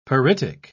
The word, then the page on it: pyritic: British English